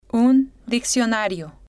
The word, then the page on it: un diccionario: female